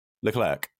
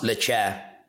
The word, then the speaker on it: Leclerc: Leclerc. – Charles Leclerc.